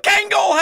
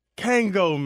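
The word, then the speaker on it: Kangol: Kango, man. It's a silent L. – Kango. – It's a silent L. – I actually think it's a very pronounced L. Kango.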